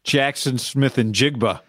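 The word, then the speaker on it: Jaxon Smith-Njigba: Jackson Smith and Jigba.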